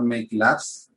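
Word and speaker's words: Meik Labs: Eh, make Labs es como una españolización de make, de crear, y por tanto se escribe como se pronuncia en español con M-E-I-K. No, no como se escribe correctamente en, inglés.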